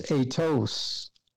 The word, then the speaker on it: aitos: Now that's spelled A-I-T-O-S. That's the Greek word for author. And the word is atos.